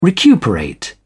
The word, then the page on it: recuperate: British English